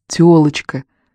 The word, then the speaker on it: тёлочка: «Телочка», — повторила она, как сумела. «Не телочка, а тёлочка», — поправил он.